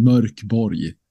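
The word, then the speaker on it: Mörk Borg: And it's Mork Borg? Bud from Bud RPG's review says it's Merk Borg. Is that how you pronounce it? So please help. – It's you can pronounce it in a lot of different ways. Right. So we've learned the last couple of years. But in Swedish, I think it's intended to sound Merk Borg.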